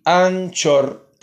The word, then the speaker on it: Anchor: sería Anchor, se pronuncia así, Anchor, tal y como se escribe, em, y bueno, en inglés Anchor.